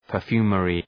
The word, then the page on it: perfumery: pər’fju:mərı